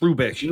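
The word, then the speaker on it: Hrubesch: I don't, how do you pronounce Rubesh. – It? Rubesh? – Horst Rubesh. – Rubesh.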